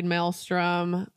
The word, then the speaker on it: Maelstrom: So we personally never got to ride Maelstrom. I think I'm saying that right. That's correct, isn't it? – It sounds perfect.